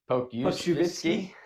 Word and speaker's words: Pokuševski: At number 18, I have Dallas taking, I'm going to completely mess up his name, but it's Alexi Pokuševski.